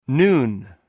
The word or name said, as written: nun